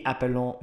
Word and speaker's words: Apollon: Unless you want to get really technical, it's Jovi Apollon Meyer. Sorry for the terrible pronunciation for those who speak French.